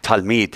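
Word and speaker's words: Talmid (תלמיד): Talmid. – Talmid, that's right.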